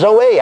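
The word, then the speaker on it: Zoe (ζωή): But now, he said it's pronounced Zoe. I saw it spelled Z-O-E, pronounced Zoe.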